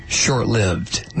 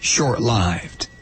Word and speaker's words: short-lived: It's never short-lived. Never. It's always short-lived.